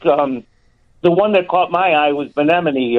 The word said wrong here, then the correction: Bieniemy: or I always mispronounce his name. – Eric Bieniemy.